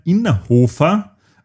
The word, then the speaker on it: Innerhofer: Es ist der Christian Innerhofer und nicht der Christian Innerkofler, wie ich gesagt habe.